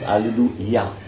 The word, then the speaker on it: Hallelujah: Often they say Hallelujah, Hallelujah. No, it's Hallelujah.